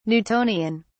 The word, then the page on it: Newtonian: British English